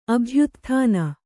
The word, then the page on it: ಅಭ್ಯುತ್ಥಾನ: abhyutthāna